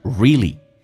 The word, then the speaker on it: really: 来看一下 really 这个单词啊，美式发音当中它有一个规则，就是 a 跟 l 碰在一起， a 可以被 l 所吸收，就变成 really.